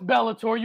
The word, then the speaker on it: Bellator: On this episode of Early Stoppage, we talk Rocky and the Boogeyman, Bobby Knuckles 9000, and I think this is pronounced Bellator. Is it Bellator? – Bellator. The prelims are going on as we speak. The prelims of Bellator. – No, it's Bellator. You heard that right.